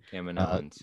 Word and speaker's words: Kaminoans: We have finally learned how to correctly pronounce that, by the way. Thank you, Rebel Scum Texan. – Yeah, again. Kaminoans.